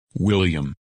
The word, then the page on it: William: English